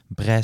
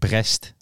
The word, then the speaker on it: Brest: Bre zeg je denk ik. Brest is het geschreven. – Ik ben niet zo goed in die Franse uitspraak.